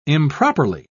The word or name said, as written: improperly